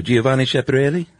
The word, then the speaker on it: Giovanni Schiaparelli: Can you say his name for everybody? – Who, Giovanni Schiaparelli? – That was good.